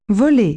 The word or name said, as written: fly